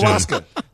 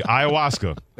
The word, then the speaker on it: ayahuasca: Ayahuasca. – Thank you. Ayahuasca.